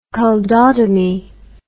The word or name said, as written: culdotomy